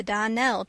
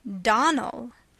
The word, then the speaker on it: Donnell: "I'm sorry," faltered Anne, vainly trying to recollect any incident of the morning connected with the Donnell children. "Clarice Almira told me that you pronounced our name Donnell. Now, Miss Shirley, the correct pronunciation of our name is Donnell. Accent on the last syllable.